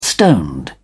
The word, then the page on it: stoned: British English